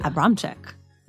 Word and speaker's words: Abramcyk: Abramcyk.